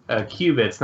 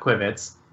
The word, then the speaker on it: qubits: I can explain in general, starting with, although I prefer your pronunciation, they're pronounced qubits, not quibits.